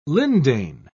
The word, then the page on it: lindane: British English